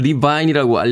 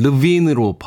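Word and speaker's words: Levine: 우리나라에서는 리바인이라고 알려져 있는데 최근에 그 토크쇼에 나와서 자기 이름을 르빈으로 발음해달라고 이야기를 하는 걸 들은 적이 있습니다. 저도 한동안 아담 리바인, 리바인이라고 발음했는데 이 미국에서는 똑같은 스펠링이어도 어, 본인이 원하는 대로 발음을 해줘야 된다고 하더군요.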